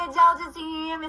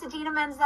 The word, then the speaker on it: Idina Menzel: Hey, Adele Dazeem, it's Idina Menzel.